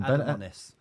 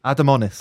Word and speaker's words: Adamonis: I can't even pronounce this one. – Adamonis. – Adamonis. – Oh, that's better.